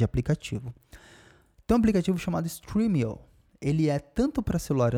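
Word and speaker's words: Stremio: Streamio se escreve S-T-R-E-M-I-O. S-T-R-E-M-I-O.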